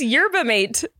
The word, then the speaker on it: yerba mate: It's yerba mate.